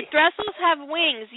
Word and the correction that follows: Thestrals: Thrasyls? How do you say that? – Thestrals.